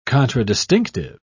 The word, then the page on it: contradistinctive: British English